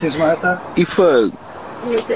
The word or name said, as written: i ebuedz